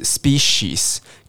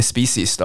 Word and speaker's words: species: 好，所以物种你去查字典，它可能音标写的是 species，可是你是可以念 species 的哦。好，因为你们呃，这个沟通上不会误解啊。像刚的 efficient，如果你念成 efficient，你不觉得这两个音质差太多了？可是 associate 跟 associate 是不是差不多？species species 是不是差不多？